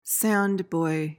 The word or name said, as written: sandboy